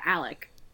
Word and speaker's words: Alec: But, yeah, did not see Alec Baldwin this week, but somebody else that I work with. Yeah, Alec. A-L-E-C. – Okay, she said it right. Like Alex.